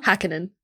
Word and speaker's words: Häkkinen: Mika Häkkinen? – Häkkinen.